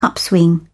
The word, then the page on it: upswing: British English